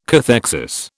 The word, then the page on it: cathexis: British English